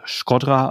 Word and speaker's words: Shkodra: So, vor allem so rund um, ich glaube, es wird Shkodra ausgesprochen.